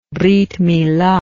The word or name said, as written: brith milah